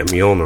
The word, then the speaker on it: Mjolnir: So yeah, Mjolnir.